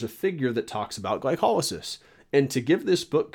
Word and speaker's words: glycolysis: Some people do pronounce it glycolysis, but I believe the correct pronunciation is glycolysis.